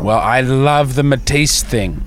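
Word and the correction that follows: Métis: Am I saying that right? – What are you talking about? – Metis? M-E-T-I-S.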